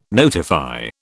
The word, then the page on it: notify: British English